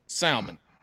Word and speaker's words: salmon: If you have a buddy and his name is spelled S-A-L, how do you say that? – Matt, that's for you. – Sal. – Mun. See? Salmon.